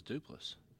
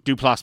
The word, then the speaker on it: Duplass: You say Duplass? – Yeah, that's how you pronounce his name. – I always thought it was Duplass. – No. – No, it's Duplass.